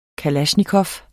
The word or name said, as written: kalasjnikov